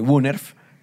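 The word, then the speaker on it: woonerf: El Wunerf esto está, es W-O-O-N-E-R-F e o El Patio Viviente, que son calles residenciales.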